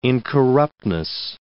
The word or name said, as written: incorruptness